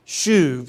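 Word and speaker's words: shuv (שוב): The Hebrew word for repentance is shuv. You can spell that S-H-U-V.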